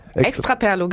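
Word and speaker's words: Extraperlo: Extra Perlo, habe ich richtig ausgesprochen? – Extra Perlo, genau, aus Barcelona.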